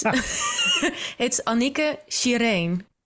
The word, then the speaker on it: Annicke Shireen: You please pronounce it properly with the rolling R. – I don't know if that's the proper pronunciation, but this is how my mom called me. It's Annicke Shireen. – Okay. – But it's, yeah, Dutch, right? Dutch pronunciation. – We lost the rolling R a long time ago. And British mouths just can't do it.